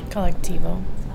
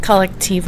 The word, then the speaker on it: Colectivo: Collectivo. – Oh, okay. TiVo? – Collectivo. It's two blocks away. – Can you say that again? – Collectivo. – Can you spell that? – C-O-L-E-C-T-I-V-O.